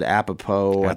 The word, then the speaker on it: Apatow: And I know he was doing something with Judd Apatow. Is that how you say his name? – Yeah.